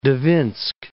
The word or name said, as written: Dvinsk